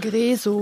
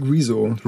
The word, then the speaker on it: Rezo: Von klar, aber äh, um es jetzt auch nochmal äh, arg verspätet aufzugreifen, ähm, Rezzo, Rezo, Rezo, wie, mhm. wie spricht man den guten Mann aus mit den blauen Haaren, der YouTuber? – Boah, ich sag Rezo.